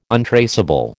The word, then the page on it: untraceable: American English